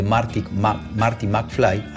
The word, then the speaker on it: Marty McFly: 88,88 millas por hora a esa velocidad exacta se trasladaba en el tiempo el DeLorean de Marty, McFly, así se pronunciaba, Marty McFly.